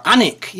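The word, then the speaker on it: Alnwick: Is that how you pronounce that? – Annick, yes. – There's an L in there.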